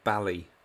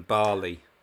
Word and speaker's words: Bali: I often wonder about how well-travelled or how knowledgeable Tom Baker actually is, because quite often he says words wrongly, like pronounce them wrongly, and the one in this episode was, it was a trick I picked up from the Firewalkers of Bali, rather than Bali.